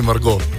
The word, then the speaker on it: Margot: Pa smo pa rekli mini Margot. In pa malo smo ga pofrancozili, tak da se T ne izgovarja.